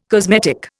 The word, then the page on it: cosmetic: Indian